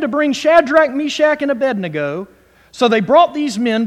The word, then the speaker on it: Abednego: Shadrach, Meshach, and Abednego. Hallelujah. Abednego. I know how to pronounce it.